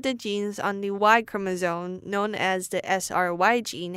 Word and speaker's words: chromosome: 那我们第一个发音重点是 chromosome，也就是我们的染色体。那这边 chromosome 分三个部分，chromosome，前面的 chrome 就是我们常用 Google 搜寻引擎，那也就是 Chrome 一样的发音。那第二个音节就是 m，那第三个音节是 zone，所以念在一起就变 chromosome。